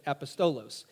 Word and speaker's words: apostolos: Apostolos, A-P-O-S-T-O-L-O-S, A-P-O-S-T-O-L-O-S, apostolos or apostolos. I've heard it pronounced a couple different ways.